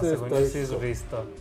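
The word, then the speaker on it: Hristo: ¿Cómo se pronuncia realmente Risto, ¿no? – Risto. – Risto. O sea, la H es muda, literal.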